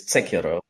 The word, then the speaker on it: Techera: So my last name is Tsekiro, which is a Romanian version of a name that might be Germanic or Magyar.